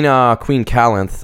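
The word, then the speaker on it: Calanthe: There's going to be a lot of spoilers, but Queen Calanth, I believe it's pronounced.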